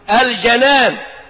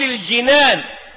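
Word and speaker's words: الجَنان: وتنطقها مفتوحة هكذا الجنان بفتح الجيم والنون، ولا تقول الجنان فيتغير المعنى ويتحول إلى جمع جنة، جمع جنة جنان وجنات، لكن الجنان القلب.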